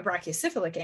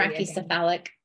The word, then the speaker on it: brachycephalic: And if you have an animal that is either a very thick short neck or a very long skinny neck or a brachycephalic animal, I never pronounced that correctly, so please say it if I did it incorrectly. – Brachycephalic.